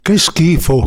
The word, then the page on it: che schifo: Italian